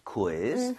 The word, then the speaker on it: quiz: 우이를 따로 다 발음해야 돼요. – Quiz. – Quiz. 그 대신에 아. 빠르게 하는 것뿐이에요. So, quiz 아니라 quiz. Quiz. 좀 이런 식으로 퀴 말고요.